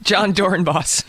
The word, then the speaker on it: Dorenbos: All right, so in sports news, John Dorenbos. – Dorenbos. – Who's your, what's, how do you pronounce it? – John Dorenbos.